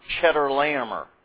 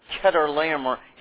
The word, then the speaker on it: Chedorlaomer: It's not Chedorlaomer. All right, doesn't have anything to do with cheese. Okay, it's Chedorlaomer.